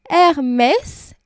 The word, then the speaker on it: Hermès: Et sur le t-shirt, c'est écrit Hermès, R, donc A-I-R, et Mess, M-E-S-S.